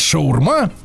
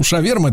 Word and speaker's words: шаурма: Если вы читаете это слово э, так же, как англоязычные люди, то должны говорить шаурма, Потому что пишется в оригинале шаварма. Ну, шаверма это наиболее близкое произнесение, да?